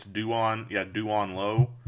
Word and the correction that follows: Duran: But he's a kid at Butler it's Duran, not Duan. Duran, excuse me.